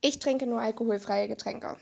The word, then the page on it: alkoholfreie Getränke: German